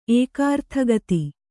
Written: ಏಕಾರ್ಥಗತಿ

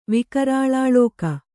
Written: ವಿಕರಾಳಾಳೋಕ

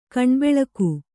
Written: ಕಣ್ಬೆಳಗು

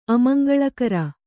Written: ಅಮಂಗಳಕಾರಿ

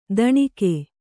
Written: ತಣುಕು

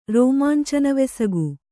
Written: ರೋಮಾಂಚನವೆಸಗು

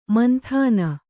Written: ಮಾಂತನ

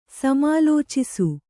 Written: ಸಮಾಲೋಚಿಸು

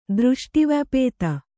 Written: ದೃಷ್ಟಿವ್ಯಪೇತ

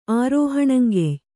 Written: ಆರೋಹಣಂಗೆಯ್

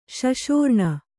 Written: ಶಶೋರ್ಣ

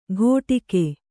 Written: ಕೊಟಿಗೆ

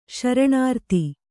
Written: ಶರಣಾರ್ತಿ

